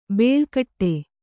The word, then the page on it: ಬೇಳ್ಕಟ್ಟೆ: bēḷkaṭṭe